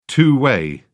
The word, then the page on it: two-way: British English